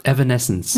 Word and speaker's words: Evanescence: Vielleicht ist es eher symbolträchtig dafür, wie sehr die Gesellschaft gespalten ist von dem Lied Bring Me to Life von Evanescence. Was übrigens die richtige Aussprache ist, ich habe es mir von Wikipedia vorlesen lassen nochmal. – Sag's nochmal. – Evanescence. – Ja, okay, das macht Sinn.